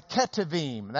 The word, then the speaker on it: Ketuvim: The third section are the Ketuvim. That's the KH sound at the end here.